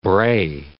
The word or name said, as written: bray